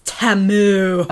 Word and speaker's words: Temu: Timu? – Probably. I don't know. It's Temu. – Oh, Temu. Sorry.